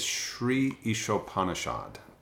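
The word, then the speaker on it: Śrī Īśopaniṣad: The book was the Shri Upanishad. I hope I'm saying it right. – I think it's Shri Ishopanishad.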